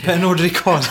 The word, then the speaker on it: Pernod Ricard: Pernod Ricard, ja, precis.